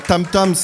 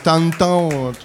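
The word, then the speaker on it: tantã: En fait, je le prononce pas bien, c'est pas tam-tam, c'est tantan.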